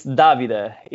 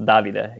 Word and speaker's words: Davide: yeah, it's Davide. It's the Italian way you say David, and so it's with the e in the end, and it's pronounced Davide.